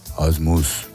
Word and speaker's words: Ormuz: La pronunciación en persa es algo parecido a Ormuz.